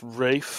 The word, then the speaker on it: Ralph: Actually, it's pronounced Rafe.